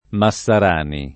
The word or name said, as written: Massarani